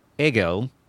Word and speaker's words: Hegel: But Fichte and Hegel, which is how that is pronounced. – Okay, geez, I've been doing the hard H's.